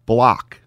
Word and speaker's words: Bullock: I wonder if the Mavs go with Luka Brunson, Dinwiddie, Finney Smith, and Bullock. And that maybe forces Looney off the floor. – Apparently he likes it pronounced Block now.